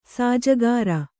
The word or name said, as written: ಸಾಜಗಾರ